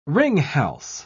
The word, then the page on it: ringhals: British English